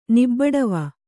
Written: ನಿಬ್ಬಡವ

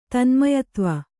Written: ತನ್ಮಯತ್ವ

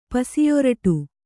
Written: ಪಸಿಯೊರಟು

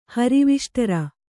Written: ಹರಿವಿಷ್ಟರ

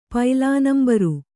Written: ಪೈಲಾನಂಬರು

